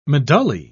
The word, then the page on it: medullae: British English